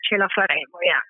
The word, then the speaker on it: ce la faremo: Ce, C-E. Then another word, L-A, and then faremo, F-A-R-E-M-O. We will do it or make it, ce la faremo, So we will emerge from this.